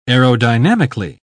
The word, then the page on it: aerodynamically: British English